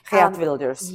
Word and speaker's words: Geert Wilders: So with, you know, big wins like Geert Wilder, I don't know if I'm saying that correctly. – Geert Wilders.